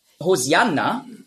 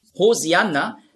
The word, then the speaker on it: Hosianna: Hosianna, ja, ist einfach die griechische Aussprache von Hoshi'anna. Hosianna, das ist die griechische Aussprache. Weil die Griechen, die haben im Alphabet kein Sch-Laut. Nurz.